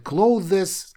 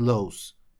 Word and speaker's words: clothes: Слово «одежда», и оно читается «clothes». То есть многие его говорят clothes, как написано, но по факту оно читается как слово закрывать, то есть как close.